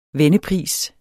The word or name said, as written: vennepris